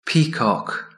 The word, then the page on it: peacock: British English